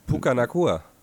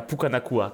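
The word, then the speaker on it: Puka Nacua: Pukenakua. – Ja, Pukenakua, genau.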